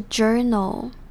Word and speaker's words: journal: Journal 这边的发音比较特别，是它的 ou 是发成 er 的声音，所以是 journal。